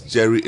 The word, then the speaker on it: Jheri: And his Jerry is spelled J-H-E-R-I.